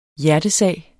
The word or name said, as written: hjertesag